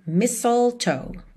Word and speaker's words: mistletoe: Então, voltando aqui, deixei a cola para você da pronúncia do mistletoe, porque se escreve M-I-S-T-L-E-T-O-E, ok? Então, mistletoe, mistletoe. Is hung where you can see, somebody waits for you, Kisser once for me.